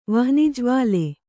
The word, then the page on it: ವಹ್ನಿಜ್ವಾಲೆ: Kannada